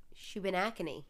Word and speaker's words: Shubenacadie: At the intersection of Route 2 and Route 224 in Shubenacadie. – Shubenacadie. – S-H-U-B-E-N-A-C-A-D-I-E.